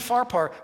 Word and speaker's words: Pharpar: Abana and Pharpar.